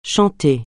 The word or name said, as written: chanter